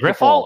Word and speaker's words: Grifol: Just Grifol.